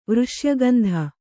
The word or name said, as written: ವೃಷ್ಯಗಂಧ